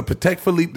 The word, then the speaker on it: Patek Philippe: You will not see me with a Patek Philippe.